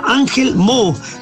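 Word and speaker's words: Angelmó: No alcanzan a pronunciar la T final, por eso esa parte se llama Ángel Mo, de Ángel Montt.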